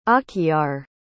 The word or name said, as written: akhyar